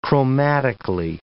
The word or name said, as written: chromatically